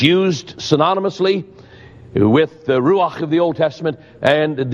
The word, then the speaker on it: ruach: I'm going to spell that word for you. R-U-A-C-H. R-U-A-C-H. Ruach.